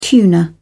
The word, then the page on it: tuner: British English